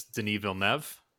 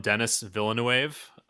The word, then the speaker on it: Villeneuve: Directed by, and I just learned how to pronounce his actual name, is Denis Villeneuve. – Ooh. – Or as I used to call him, Denis Villeneuve. – Villeneuve. I did it like Denis Villeneuve. But it's Denis Villeneuve.